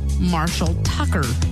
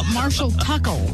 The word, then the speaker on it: Marshall Tucker: Linda says, I don't know why I have a hard time pronouncing the Southern rock band Marshall Tucker. It always comes out Marshall Tuckle.